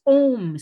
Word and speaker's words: Ogham: One thing that I saw there I'd never seen before, never even heard of, was Ohm stones. Ohm is spelled O-G-H-A-M.